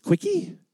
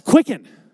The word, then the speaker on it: Quicken: It's Quickie? Quickie? Said, no, you don't want a Quickie. It's Quicken.